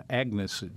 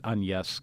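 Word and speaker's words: Agnès: Her name was Agnes, is what you and I would say if we saw it. She pronounces it Agnes because she's French.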